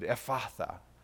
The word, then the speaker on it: Ephphatha: So at least we can pronounce it like Jesus would, Ephphatha.